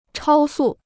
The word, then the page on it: 超速: chāo sù